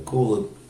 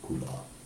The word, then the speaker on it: kula: So sometimes people accept Diksha either from the Kula guru, Kula. It's actually Kula, but sometimes it's pronounced Kul.